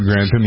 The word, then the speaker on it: Grantham: Grantham? – Lord Grantham, yeah.